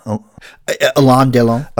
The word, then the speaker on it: Alain Delon: Alain Delon.